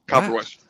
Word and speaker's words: Koperweis: Is it Koperweis? How's it go? – Yeah, Koperweis.